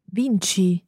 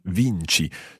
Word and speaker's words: vinci: But you win. – Vinci. – Vinci. So the C-I there gives us the ch sound.